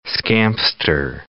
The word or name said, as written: scamster